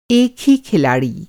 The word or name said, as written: खिलाड़ी